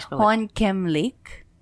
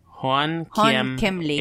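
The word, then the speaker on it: Hoàn Kiếm: Hoan Kiem Lake. – Hoan Kiem Lake. H-O-A-N-K-I-E-M.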